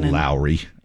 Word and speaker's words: Lauri: It looks like Lowry, but they pronounce it Lowry Markkanen.